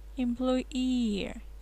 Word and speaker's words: employee: Employee, employee, этот переводится как служащий, работающий по найму. Employee. Ударение на double E, которое в конце как раз прописывается.